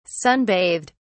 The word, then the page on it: sunbathed: British English